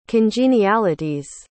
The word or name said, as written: congenialities